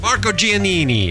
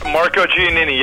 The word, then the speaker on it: Marco Giannini: Marco Giannini. Is that how your name's pronounced? – Marco Giannini, yes, sir.